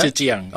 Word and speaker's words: Zhejiang: Zhejiang, okay.